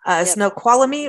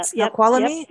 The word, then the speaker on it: Snoqualmie: You're going by Lake Cochise and Snoqualmie, right? Am I saying that right? – Yeah. – Snoqualmie? – Yep.